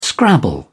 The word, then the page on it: Scrabble: British English